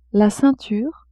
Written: ceinture

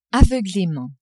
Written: aveuglement

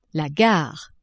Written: gare

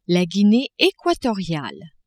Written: Guinee equatoriale

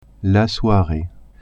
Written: soiree

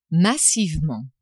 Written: massivement

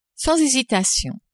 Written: sans hésitation

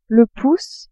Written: pouce

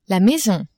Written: maison

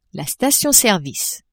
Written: station-service

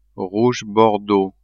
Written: rouge bordeaux

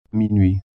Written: minuit

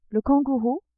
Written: kangourou

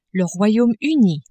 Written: Royaume-Uni

